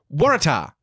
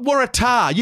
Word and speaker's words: Waratah: Waratah? It's not Waratah. You don't emphasise any syllables in there.